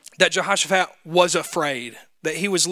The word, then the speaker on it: Jehoshaphat: The king of Judah right at this point is Jehoshaphat, not Jehoshaphat, Jehoshaphat.